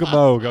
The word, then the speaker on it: Moog: Moog. – Yes, got it. – There it is. – Okay. – There we go. Two applause. – I had to think Vogue, Moog.